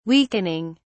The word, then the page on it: weakening: British English